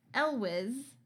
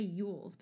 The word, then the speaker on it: Elwes: Bruce Greenwood as Keppel and Carrie Elwes. I looked up how to say his name because I love this actor, but I always want to say Yules, but that's not right. It's Elwes as Bundy.